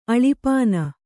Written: ಅಳಿಪಾನ

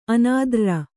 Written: ಅನಾದ್ರ್ರ

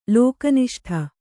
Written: ಲೋಕನಿಷ್ಠ

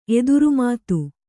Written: ಎದುರುಮಾತು

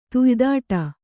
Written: ತುಯ್ದಾಡು

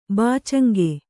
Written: ಬಾಚಂಗೆ

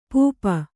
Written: ಪೂಪ

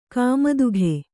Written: ಕುಮುದಿಕೆ

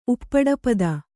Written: ಉಪ್ಪಡಪದ

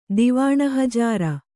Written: ದಿವಾಣಹಜಾರ

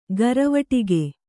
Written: ಘರವಟ್ಟಿಗೆ

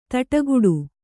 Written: ಥಟ್ಟುಗೆಡು

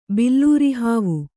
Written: ಬಿಲ್ಲೂರಿಹಾವು

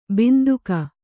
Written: ಬಂದುಕ

